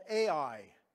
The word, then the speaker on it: Ai: And then in this, like, the story moves on after that, and they go to the next city, and it's a city called Ai, not artificial intelligence. But it's pronounced AI. Okay?